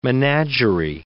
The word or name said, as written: menagerie